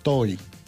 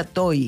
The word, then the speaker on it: Tohi: ¿Cómo se escribe? – T-O-H-I. Toy. – Ah, T-O-H-I. – Sí. – Como Toji. – Sí. – Toji y se pronuncia Toy.